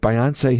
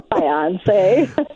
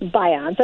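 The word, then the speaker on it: Beyoncé: And I look at, I watch the news, and I just say Walter Cronkite, you know, would not be announcing that Beyonce had twins. – Beyonce. – Oh, I'm sorry, Beyonce. I'm sorry, it's Beyonce, right? – Yes, I think you'll find it's pronounced Beyonce, at least on this podcast.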